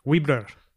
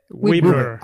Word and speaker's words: Weeblr: Porque en español sería algo así como bla, bla, Se, pronuncia, como, se escribe. Weebler. – Entonces, Weebler.